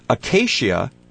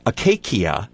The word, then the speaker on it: acacia: Some scientists might pronounce acacia to, according to the Latin pronunciation, acacia, but it would be silly and pretentious in regular discourse.